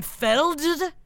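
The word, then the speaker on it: Feldt: It's by a man named Sam Felted. I don't know how to say it. It's felt, the word felt, but with a D in it.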